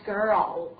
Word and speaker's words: girl: 好，另外呢，我们来看一下这个最后一个等待的最后一个单词，就是这个 girl 啊，大家注意一下它的发音呢啊，在这个 r 和这个 l 之间呢，其实一般呢，我们为了方便发音呢，加上了一个弱化元音好，所以发出来是 girl。